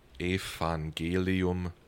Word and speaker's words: Evangelium: And then in German, if you have a V at the start of a word, you pronounce it as an F. So Evangelium.